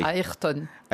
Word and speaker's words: Ayrton: Au Brésil, on prononce pas Ayrton, a priori. – Ayrton. Ayrton Senna.